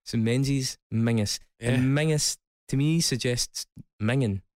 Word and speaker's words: Menzies: And for all those outside of Scotland as well, for some inexplicable reason, we pronounce the word Menzies as Mingus.